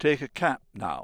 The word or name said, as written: cap